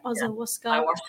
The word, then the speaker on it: ayahuasca: I mean, they do, you know, ayahuasca and, or how do you say it? Ayahuasca.